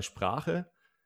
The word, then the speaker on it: Sprache: Aber zum Beispiel bei Sprache, ähm, es gibt Wörter, die sich darauf reimen, die aber mit kurzem Vokal ausgesprochen werden, zum Beispiel Sache. Und wahrscheinlich ist das auch einer der Gründe, warum du äh, Sprache kurz aussprichst, weil du denkst, ja, es gibt ein anderes Wort, was auch kurz ausgesprochen wird. Und dann übertrage ich das darauf, aber es, in dem Fall lässt sich halt nicht darauf übertragen.